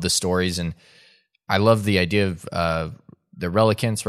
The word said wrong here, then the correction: replicant: right? Is that what it is? The pronounce replicants. – Replicants. Thank you. Replicants. I keep thinking relicanth, which is a Pokemon, and that's not it at all.